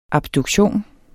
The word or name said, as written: abduktion